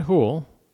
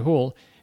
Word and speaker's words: Houle: So lately, David Hoehl, his name is spelled H-O-U-L-E, David Hoehl, has been writing lots of books.